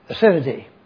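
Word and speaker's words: aseity: The way that I pronounce this word is deity, D-E-I-T-Y. Take off the D and put A-S. A-S-E-I-T-Y. Acidity.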